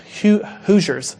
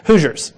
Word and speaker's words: Hoosiers: One of my favorite movies is Hoosiers. Sorry, it'd be good if I say it right. Hoosiers.